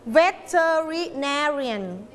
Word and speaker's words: veterinarian: veterinarian พยางค์ไหนคะ veterinarian so first second third fourth or fifth พยางค์ที่ fourth ก็